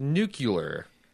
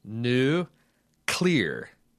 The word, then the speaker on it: nuclear: But it's like there's an accent and then there's adding like nuclear. There is no syllable. They're adding a syllable that doesn't have any – I mean it's not like it's some crazy-ass French word. It's new, clear.